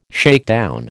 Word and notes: shakedown: American English

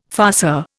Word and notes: farceur: Indian

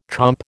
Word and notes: chomp: American English

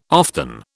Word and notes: often: British English

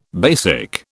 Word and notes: BASIC: British English

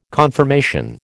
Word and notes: conformation: American English

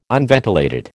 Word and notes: unventilated: American English

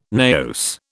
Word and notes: naos: British English

